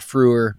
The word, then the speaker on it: Frewer: Max Fearman or Furman? – Oh, Matt Frewer. Matt Frewer, the actor.